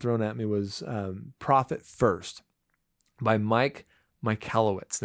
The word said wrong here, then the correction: Michalowicz: Now his name is kind of hard to say. I'm sure I butchered it but it's Mike Michalowicz.